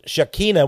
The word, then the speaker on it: Shekinah: The Jews, I think, would pronounce it Shekinah, which also means, by the way, you may divine feminine, you're right.